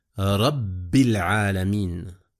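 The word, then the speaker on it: رَبِّ الْعَالَمِينَ: « Rabbil alamin », il y a aussi une shadda, une lettre double et le ba.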